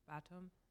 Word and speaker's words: Batum: Batum.